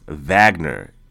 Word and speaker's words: Wagner: Now, I think it's pronounced Wagner, even though it's spelled Wagner. W-A-G-N-E-R. I think it's pronounced Wagner because they're, I believe they're German.